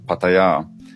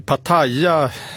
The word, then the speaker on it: Pattaya: Jag menar, Pattaya, som väl jag och många svenskar säger, eller Pattaya som jag hör att uttala det, är väl snarare känt för annat än vila och återhämtning, eller hur?